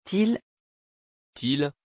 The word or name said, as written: Til